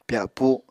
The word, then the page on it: pyapu: Apatani